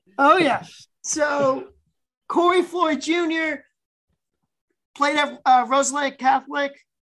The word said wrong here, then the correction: Roselle Catholic: Correct me if I'm saying that wrong. – Roselle Catholic. Come on. I'm from Jersey. You can't be doing that to me. – Okay. Roselle Catholic.